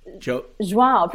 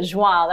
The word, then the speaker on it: João: And then you take this trip, because your now husband, I'm going to try and get the name right, Joe. – João, pretty Joa? Close. Yeah. Well, John in Portuguese, but João. That was pretty good.